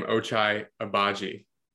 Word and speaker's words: Agbaji: So honestly, one of my favorite players in the draft was, I'm still having trouble pronouncing his name, Ochai Agbaji. – I think you nailed it. – Did I? – I think so. That sounds about right to me.